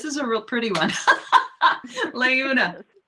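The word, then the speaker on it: Laeuna: And the actual pronunciation is Leuna? – Leuna.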